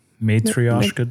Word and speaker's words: Matryoshka: Matryoshka doll.